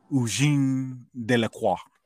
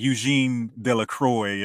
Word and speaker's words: Eugène Delacroix: I want to say it correctly, and it's because it's a French name, it's Eugène Delacroix. Or Eugene, or it looks like Eugene Delacroix if you're from the South, like I am, but Eugene Delacroix.